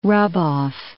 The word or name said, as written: rub-off